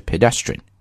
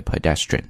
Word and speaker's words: pedestrian: 英式英语呢在这个 e 当中读 pit 这个音，所以是 pedestrian， but American English tends to pronounce like a pedestrian，所以是 e 这个音都可以，说 pedestrian or pedestrians both are fine。